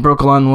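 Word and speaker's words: Brokilon: And here is her first magical powers, which we needed to see, and then her going into the Brokala... I always forgot to pronounce it. Sorry, guys, just watching this amazingness. Brokalon, excuse me, Brokalon Woods.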